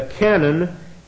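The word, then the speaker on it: canon (κανών): And again, when I talk about the canon, C-A-N-O-N is what I'm after, not double N. That's the artillery piece. The canon comes from the Greek word canon, K-A-N-O-N, canon, K-A-N-O-N, long O, canon.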